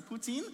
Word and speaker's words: poutine: This next one, I hope I'm pronouncing it right, is poutine.